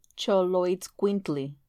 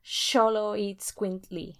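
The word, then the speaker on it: Xoloitzcuintli: So they also had a really, really cool relationship with dogs or companions called Zoloitzcuintil. I didn't say that right. – Yeah, I think it's Choloitzcuintli. – Oh god, Choloitzcuintli. – Yeah, the X is a ch sound.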